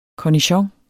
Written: cornichon

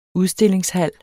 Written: udstillingshal

